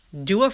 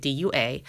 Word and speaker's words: Dua: I know, I think it was last week's episode, I mentioned Tom Ford's new fragrance, Rose Prick, which Dua Fragrances, and I've been pronouncing this wrong the whole time. I have been pronouncing it D U A, but apparently it's pronounced Dua.